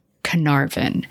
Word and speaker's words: Caernarfon: This is the town of Caernarfon. Now, it looks like Caernarfon. It's in Northern Wales, but it is spelled C-A-E-R-N-A-R-F-O-N. And it's in it's a region of Gwynedd, G-W-Y-N-E-D-D.